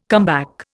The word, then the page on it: comeback: Indian